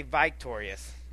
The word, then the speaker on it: victorious: We'll see who comes out victorious. I'm about to say victorious. I was putting the emphasis on the wrong syllable.